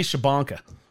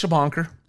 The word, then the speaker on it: Shubhankar: Feels like it should be Shubhankar, but it's Shubhankar.